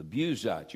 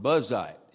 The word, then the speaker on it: Buzite: Then was kindled the wrath of Elihu, the son of Barakel, the Buzite. You remember we were introduced in the book of Job. I picked it, Chris Wilbur. I pronounced this word Buzite. It's actually pronounced Buzite.